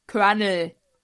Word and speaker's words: Colonel: Oder in, äh, in Colonel ist auch kein R. – Colonel. – Ja. Wie wird's ausgesprochen? – Colonel. – Ja, danke. Ist da ein R im Aus in der Aussprache?